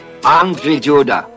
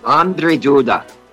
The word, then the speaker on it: Andrzej Duda: Over, and During the break, I was learning how to pronounce Andrzej Duda. Andrzej Duda. That's the pronunciation of the president of Poland. That was my best effort.